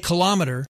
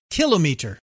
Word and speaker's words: kilometer: It's true that I sometimes say kilometer and other times kilometer. Like many words in our wonderful and colorful English language, both pronunciations are widely used. Generally, the British say kilometer, which resembles the French kilomètre. Americans usually prefer kilometer.